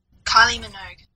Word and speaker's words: Kylie Minogue: Kylie Minogue.